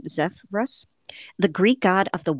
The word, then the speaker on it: Zephyrus: Zephyrus. I think that's what yeah. it is. That's what uh-huh. how I have it uh, phonetically, whatever.